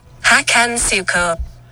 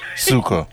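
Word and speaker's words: Hakan Şükür: Hakan Shukor. – It's Shukor. It's not Shu. – Yeah. Shukor.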